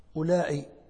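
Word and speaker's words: أولاء: الموضع الثاني لزيادة الواو قال وأولائي، أولائي هذا اسم الإشارة الذي كتبناه قبل قليل، أولائي للإشارة إلى جمع الذكور والإناث، أولائي رجال وأولائي نساء، ولأنه يلتبس كما قلنا بالاسم الموصول الأولى جعلوا في اسم الإشارة واوا تكتب ولا تنطق للتمييز، تقول أولائي رجال وأولائي نساء.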